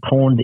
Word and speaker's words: pwned: And pawned is spelled P-W-N-E-D.